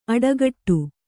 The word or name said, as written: ಅಡೆಗಟ್ಟು